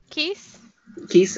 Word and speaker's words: Keese: Keese or Keese? How do you pronounce it? Is it Keese? – Keese, okay. – Just like geese, I always kind of thought it was like...